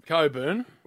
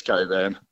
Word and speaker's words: Cockburn: How do you call it? Because Jim calls it Coburn. – It's Coburn. – What? But it's spelt Cockburn, isn't it?